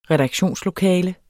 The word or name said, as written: redaktionslokale